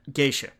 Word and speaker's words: Geisha: Memoirs of Genosha, or no, how do you pronounce that? – Geisha. Memoirs of Geisha. Geisha. Yeah.